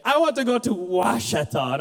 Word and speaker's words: Ouachita: He goes, it's O-U-A. Dad, that's Ouachita. Dad, this is not fair. Let me just apologize for English. Dad, it's not fair. That's like a fifth level word. It's wash, W, you pronounce it that way.